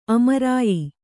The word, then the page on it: ಆಮ್ರಾಯ: āmrāya